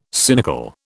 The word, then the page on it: cynical: British English